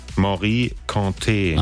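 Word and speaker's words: Mory Kanté: Wie wird's ausgesprochen? – Morikante. – Ach so, guck mal, das war also falsch.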